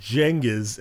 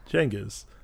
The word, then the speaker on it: Genghis: I will now say it correctly, and I always like to say it's like Jenga with an is at the end. Genghis.